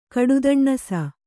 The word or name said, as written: ಕಡುದಣ್ಣಸ